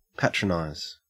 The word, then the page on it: patronize: Australian English